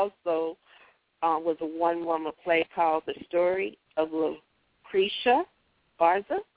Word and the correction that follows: Lucretia Borgia: Did I pronounce it right? – Lucretia Borgia, yes.